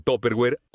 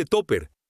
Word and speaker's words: Tupperware: En México es pronunciada comúnmente como Tupperware o solamente Topper.